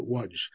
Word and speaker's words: Łódź: I understand in Polish, they pronounce it Łódź.